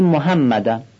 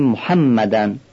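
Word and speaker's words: محمدٌ: اینجا باید هی جیمی رو با یک گرفتگی در گلو شبیه صدای گلو صاف کردن به اون صورت تلفظ کنیم، که اکثر شما عزیزان این رو بلدید. اصلا بعضی مناطق مثل کردستان، خوزستان، اصفهان، یزد، بسیاری از استانها تلفظ صحیح هی رو در لهجه محلیشون هم استفاده میکنن. بنابراین به جای محمدن چی باید بگیم؟ باید بگیم محمدن.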